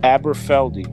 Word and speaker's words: Aberfeldy: Aberfeldy. It's spelled A-B-E-R-F-E-L-D-Y.